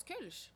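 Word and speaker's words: Kölsch: Euh, tu le prononces Kulch, en fait, c'est ça c'est l'inspiration allemande.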